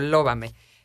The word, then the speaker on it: Lóbame: Lóbame, L-O-B-A-M-E, con acento en la O, Lóbame.